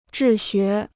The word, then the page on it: 治学: zhì xué